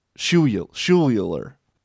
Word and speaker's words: Schuyler: Who pronounces this Schuyler? S-C-H-U-Y-L-E-R. How is that not Schuyler? – It's Schuyler, buddy. Trust me.